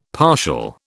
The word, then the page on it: partial: British English